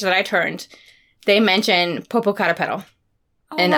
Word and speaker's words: Popocatépetl: Oh, Popocatépetl.